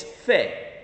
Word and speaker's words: fe: The Spanish word for faith is very simple. It's one syllable. It's fe.